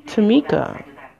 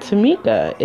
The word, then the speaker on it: Tamika: Tamika is my first name. And Tamika is supposed to be a Japanese name. Let me spell it for you. T-A-M-I-K-A.